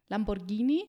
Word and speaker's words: Lamborghini: Nein, da haben wir jetzt wieder das Problem mit dem H. Wie du richtig gesagt hast, muss es dann richtig heißen Lamborghini.